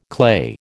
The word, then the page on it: clay: American English